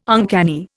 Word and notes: uncanny: Indian

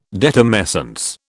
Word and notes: detumescence: British English

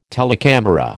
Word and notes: telecamera: American English

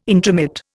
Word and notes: intromit: Indian

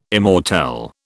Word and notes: immortelle: British English

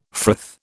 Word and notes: frith: British English

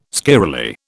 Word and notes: squirrelly: British English

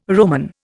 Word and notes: Roman: Indian

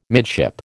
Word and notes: midship: American English

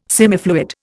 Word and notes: semifluid: Indian

